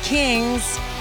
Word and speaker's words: Qings: Y101, you just heard You and Me by The Kings. And The Kings is spelled with a Q, by the way. Q-I-N-G-S.